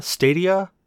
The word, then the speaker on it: Stadia: Google with the, and somebody correct me, I'm going to say this wrong, the Stadia? – Stadia. – Stadia, thank you.